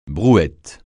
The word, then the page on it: brouette: francais